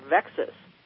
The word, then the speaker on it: VEXAS: There's another one called VEXAS, rhymes with Texas, and it's an acronym.